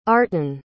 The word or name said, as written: artan